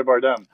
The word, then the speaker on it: Bardem: How do you pronounce Javi? Bandem? Bandem? – Oh, Javier Bardem.